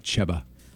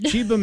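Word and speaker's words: Cheba Hut: Somebody said, it is pronounced Chiba Hut, not Cheba.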